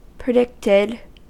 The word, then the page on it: predicted: American English